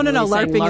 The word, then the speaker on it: LARPing: For those who don't know, LARPing, like L-A-R-P, stands for Live Action Role Play. – What was your LARPing character? And tell me if I'm saying that wrong. This is my first time. – No, LARPing is correct.